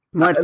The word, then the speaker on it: Muertos: There's going to be a Dia de los Muertos. Am I saying that correctly in Spanish? – Muertos. Let's just say Muertos.